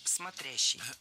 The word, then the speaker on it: смотрящий: Смотрящий – неверно. Правильный вариант – смотрящий. Ударение падает на второй слог. В исходном глаголе ударение тоже плавает. Смотрит, но смотрел. Опять же, надо запомнить, что в причастии оно стоит за корнем – смотрящий.